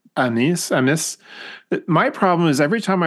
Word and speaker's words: amice: In the church is the amis or amis. Am I pronouncing it right, Robert? – I would almost pronounce it amice.